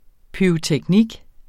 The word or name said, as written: pyroteknik